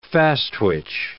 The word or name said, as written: fast-twitch